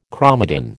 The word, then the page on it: chromatin: American English